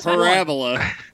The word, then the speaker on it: Parabola: It's parabola. You put the wrong emphasis on the wrong syllable.